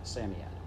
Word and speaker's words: Psammead: Psammead is pronounced Psammead.